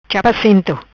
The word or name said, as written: Chapa Assento